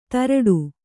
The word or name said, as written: ಧಾರಾಟ